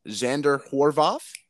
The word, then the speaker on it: Xander Horvath: You put in, I'm hoping I pronounced this right, Xander Horvath. – Yep.